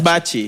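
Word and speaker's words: Baci: It's Bachi.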